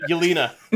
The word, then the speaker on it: Yelena Belova: Yolanda Belova. I think that's how you say it. Yelena. – Yelena, there you go. – Yelena Belova.